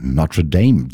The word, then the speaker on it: Notre Dame: Und einer der neuesten Sets kommt von Notre Dame oder Notre Dame, wie man ihn gerne aussprechen würde. Ich mach's französisch, weil er eben aus Paris kommt, und er sagt, es ist schon seine vierte Veröffentlichung auf Dynamic und es macht immer wieder Spaß, bei dem Label zu veröffentlichen.